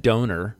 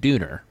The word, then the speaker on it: Döner: That is apparently the pronunciation. I'm sorry. It looks like Donner, uh-huh. But I was told by the man working, it's called Döner kebabs. – How is it spelled? – It's it's Donner a lavash, party? D-O-N-E-R, I think.